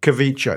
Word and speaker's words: Kvicha Kvaratskhelia: Kvicha. – It's Kvicha Kvaratskhelia. To his what? – Apparently the K is silent. – Kvaratskhelia.